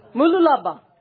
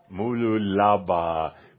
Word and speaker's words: Mooloolaba: Mooloolaba. – Mooloolaba, Queensland, Australia.